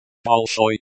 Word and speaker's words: Большой: Большой, stress on the second syllable, oi, sound like, oi, in, boy.